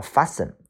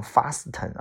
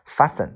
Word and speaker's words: fasten: 下一个单词叫做 fasten，这个单词一定不能读成 fasten 啊，这个呃 t 不发音。 fasten 它的意思是拴牢，把什么东西拴在什么上边啊，需要认识一下。